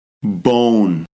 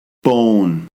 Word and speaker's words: bone: Bone. O. Bone. Magic E. E is silent. O with an E at the end makes the sound O. O. Bone.